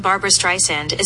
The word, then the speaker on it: Barbra Streisand: Barbra Joan Barbra Streisand is an American singer, actress, and said it right. – Yes, but she did say Barbra Streisand. – Yeah, she said it right.